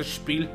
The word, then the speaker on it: Spiel: eh, Spiel creo que se, pronuncia Spiel, así como. Spiel, ajá, Spiel, sí, eso sí, eso sí es la parte alemana, eso ah. no hay pedo, güey. Pero el otro es Jars, es J-A. J-A-H-R-E-S.